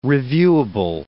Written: reviewable

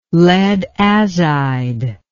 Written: lead azide

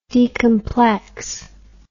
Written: decomplex

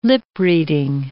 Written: lip-reading

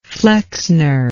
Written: flexner

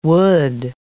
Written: would

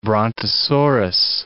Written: brontosaurus

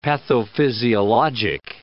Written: pathophysiologic

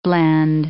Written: blende

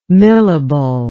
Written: millable

